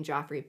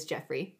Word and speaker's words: Geoffrey: In 1650, Sir Thomas's grandson, Geoffrey, although it's spelled with a G, so I kept saying Joffrey, but it is Geoffrey Reed, would often come around and dote on Anne.